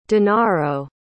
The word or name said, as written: denaro